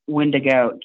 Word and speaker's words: Wendigo: He wants to know, have you heard about the, I don't know if I say it right, Wendigo? – Wendigo, yes, I have.